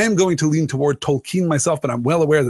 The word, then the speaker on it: Tolkien: There are two accepted pronunciations, J.R.R. Tolkien and J.R.R. Tolkien. Technically, it should be Tolkien because it is of a Germanic root, and as the professor himself would tell us, therefore pronounced Tolkien.